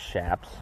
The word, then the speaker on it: chaps: But it's pronounced chaps, and we call them leggings.